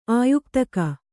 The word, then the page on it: ಆಯುಕ್ತಕ: Kannada